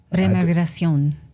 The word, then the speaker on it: Remigración: Here's how would you say that? Re-immigration? – Remigración. – There you go.